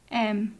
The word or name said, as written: em